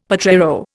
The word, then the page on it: potrero: Indian